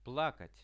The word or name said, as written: plakat